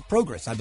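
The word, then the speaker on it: Prograis: I've always struggled pronouncing his last name. – Progress. I believe it's Progress.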